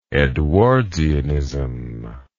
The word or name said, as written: edwardsian